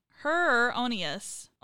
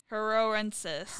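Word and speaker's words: hereroensis: Heronius. – Onus. There's like an extra syllable in there.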